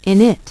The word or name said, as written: init